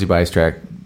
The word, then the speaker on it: Bystrak: Hey, we have Rosey Bystrak.